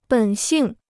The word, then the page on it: 本性: běn xìng